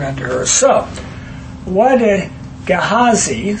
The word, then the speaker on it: Gehazi: Gehazi. – Gehazi. Gehazi. Like G-A-Y-H-A-Z-E-E.